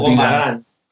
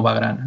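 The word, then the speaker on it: Vagrant: con ¿cómo se pronuncia? Bigran. – Con eh, bueno, eh, el sitio cool sería Bigran, pero el sitio cool es Bigran. Nosotros lo, desde aquí lo llamamos Vagran.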